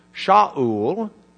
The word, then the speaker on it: Shaul: Saul in Hebrew, Shaul is the way it would be pronounced in Hebrew.